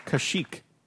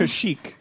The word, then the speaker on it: Kashyyyk: X-I-L-I-N-X. – Tech company. – Yes, it is a tech company. You are right. Okay, Sarah. Kashik. I'll spell that one for you, to be fair. K-A-S-H-Y-Y-Y-K. – Can you say it again? The spelling confused me. – Kashik.